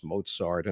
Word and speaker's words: Mozart: I knew it was Mozart and not Mozart, and you know, Wagner and not Wagner.